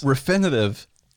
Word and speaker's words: Refinitiv: That's what Refinifty. I don't know what that means. – Refinitiv. – Okay. Some would pronounce it Refinitivs.